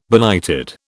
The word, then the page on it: benighted: British English